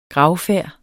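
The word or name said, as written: GRAVFÆRDERNES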